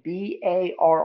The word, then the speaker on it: barre: The uh, you know uh, they call it bar, but it's spelled B A R R E it's like how is that pronounced bar the english language baffles me I,